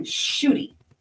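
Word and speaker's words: Ncuti: Shooty. Somehow NC makes SH. – Okay. – Shooty, like S-H-O-O-T-Y.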